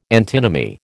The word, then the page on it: antinomy: American English